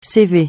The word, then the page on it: CV: francais